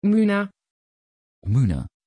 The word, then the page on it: Muna: Dutch